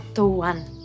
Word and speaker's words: Doğan: Doğan. Das G, das spricht man nicht mit. Das ist ein türkisches Dehnungs-G.